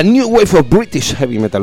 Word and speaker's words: New Wave of British Heavy Metal: La New Wave of British Heavy Metal, ¿lo he pronunciado bien, profesor? – Bueno, sí.